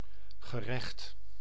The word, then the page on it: court: /kɔː(ɹ)t/; /kɔːt/; /koʊɹt/